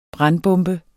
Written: brandbombe

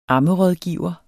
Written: ammerådgiver

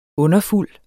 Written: underfuld

